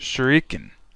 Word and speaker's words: shuriken: Shuriken.